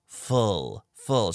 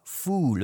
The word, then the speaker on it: full: Full, full. So it's not fool, but full.